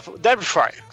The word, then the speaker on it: Derbyshire: Derbyshire, né? Pronúncia inglesa de UK,